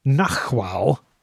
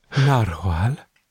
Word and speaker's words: narwhal: However, his pronunciation of nachwhal... – narwhal? – Yeah.